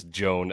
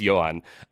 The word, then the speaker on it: Yoan: And I actually made sure to look up the pronunciation, because I was like, there's no way this dude's name is Joan Adon. And it's not. It's Yoan.